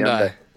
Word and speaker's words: Hyundai: Hi- red Hyundai, is that how you say it? – Red Hyundai. Hyundai. Yeah.